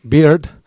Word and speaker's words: Beard: pronúnciese la D, Beard, para no llamarle señor Frank Cerveza, que curiosamente se llama Beard.